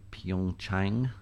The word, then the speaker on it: Pyeongchang: So let's talk about the Olympics because you're gonna be reporting on it for us, the Winter Olympics in Pyeongchang. Am I saying that correctly, yes.